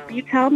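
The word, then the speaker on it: Buttell: It's on there as Betsy Butel Music, and my last name is spelled B-U-T-T-E-L-L. I know that sometimes my last name is spelled wrong a lot, so. – Yeah. – But Betsy Butel Music,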